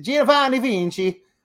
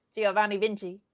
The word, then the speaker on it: Giovanni Vinci: Giovanni Vinci. – No, that's not how you say it. Giovanni Vinci.